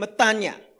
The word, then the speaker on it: metanoia: I've heard a Greek speaking person saying metanoia, right? That's how they seem to say it.